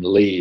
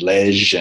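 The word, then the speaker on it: Leigh: That's why my name Lee is, like, I've been called everything over here because it's spelled L-E-I-G-H. I've been called Leg and Lej and Lev and Leg or everything.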